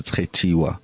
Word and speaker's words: Khetiwa: So when I say my social media, I say it as Kheti, because it's spelled like that. So it's a pretty name. It's Khetiwa.